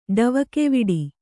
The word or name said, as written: ಡವಕೆವಿಡಿ